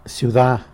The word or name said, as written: syuda